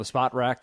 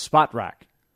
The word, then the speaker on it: Spot Rack: This was big news that we did verify, Chase, that it is Spot Rack, not Spotrack, as some like to call it.